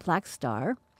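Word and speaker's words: Blackstar: It says, on Friday, the day he turned 69, Bowie released a new album pronounced Black Star.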